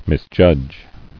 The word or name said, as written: Misjudge